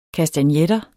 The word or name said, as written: kastagnetter